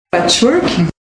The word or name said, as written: Patchwork